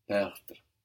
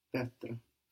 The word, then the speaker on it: perdre: Il ne veut rien perdre. – Very good. If you put more accent on the bit at the beginning, perdre, and let the rest kind of fall out, I think you will find the pronunciation easier.